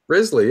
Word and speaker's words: Risley: Is it Risley? – Risley, yeah. R-I-S-L-E-Y. – Okay. I went to school with a few with the same spelling, and they were Rileys. – With an S in there? – Yeah. Like okay. A silent S.